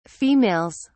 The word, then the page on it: females: British English